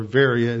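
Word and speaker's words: Veria (Βέροια): And so he goes with Silas down to Berea, or Veria as it's pronounced in Greek.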